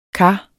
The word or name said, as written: kar